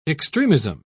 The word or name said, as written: extremism